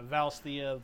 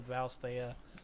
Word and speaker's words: Valisthea: It's like Valisthea, is that right? – Yeah, Valisthea, Valisthea.